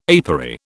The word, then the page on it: apery: British English